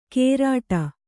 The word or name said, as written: ಕೇರಾಟ